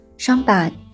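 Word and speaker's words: Champagne: 听完香槟酒的概念后，再教大家一个香槟的法语单词发音：双板、双板、双板。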